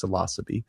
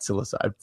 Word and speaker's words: Psilocybe: I know it sounds weird. That is how it's pronounced, psilocybe, not psilocybe. For the mushroom, it is called psilocybin, the drug inside of it.